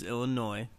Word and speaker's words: Illinois: And it's Illinois, by the way. The S is silent.